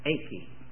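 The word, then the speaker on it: eike (εἰκῆ): And the word is E-I-K-E, eike, eike. The E is pronounced as an A in the Greek, eike.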